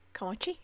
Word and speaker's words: Comanche: Comanche, Comanche, yeah.